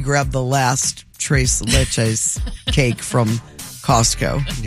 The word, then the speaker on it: Tres Leches: Tres Leches.